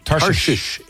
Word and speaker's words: Tarshish: I think it was at Tarshish? Am I saying that right? – Tarshish is correct.